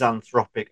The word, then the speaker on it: Anthropic: Is that how it's pronounced? – That is Anthropic.